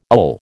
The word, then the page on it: ill: American English